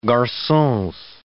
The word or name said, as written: garcons